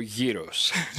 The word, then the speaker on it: gyros: Okay, so ignore the G, go straight to the Y, and go gyros.